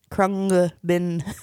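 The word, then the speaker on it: Khruangbin: It's an NG, so an ng. – Ng. – Ng, yeah. Krungbin. Okay, great.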